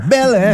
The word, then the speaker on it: Belém: Belém.